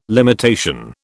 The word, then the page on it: limitation: British English